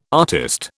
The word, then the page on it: artist: British English